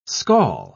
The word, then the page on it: scall: British English